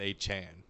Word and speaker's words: Achane: That's literally A-chan. What he says. – Oh, that's how he pronounces it. – It says Devon Achane in the media guide, which is what a lot of people were going by. But it is from his mouth, Devon Achane.